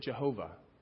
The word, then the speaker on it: Jehovah: In Hebrew, it's pronounced Yahweh. When we transliterated it into English, we pronounce it Jehovah.